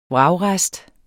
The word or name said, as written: vragrest